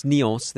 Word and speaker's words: Nyons: Yeah, it's Nyons. They pronounce that last S.